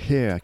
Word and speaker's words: H31R: Oui, Hair, qui s'écrit H31R mais qui se prononce Hair,